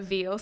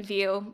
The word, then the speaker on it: Veale: It'll be like, but the way to pronounce it is Tamsyn, Moana, Veale. So like Tamsyn as normal, Moana, kind of like the movie, and then Veale, like the meat.